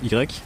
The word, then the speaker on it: Ygrek: Y-G-R-E-K, donc on prononce Y.